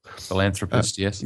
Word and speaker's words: philanthropist: Gary, the other person, the other main person in Aeroscene is a philanthropist, or however you pronounce that. – Philanthropist, yes. – Yep, it's a bit hard for a Dutchman to pronounce.